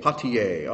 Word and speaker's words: Poitiers: Hilary of, how do you pronounce that? Who's a French speaker in here? Poitiers? Poitiers, all right.